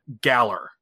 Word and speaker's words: Galar: It's called Galar. – Yes. – Which, by the way, I'm just going to point out rhymes with Valor.